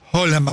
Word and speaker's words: Haaland: Es decir, Haaland, vamos a pronunciarlo como se pronuncia en Noruega, digamos, ahí es la figura del arquero, pero hay toda una estructura de equipo fuerte, ¿eh?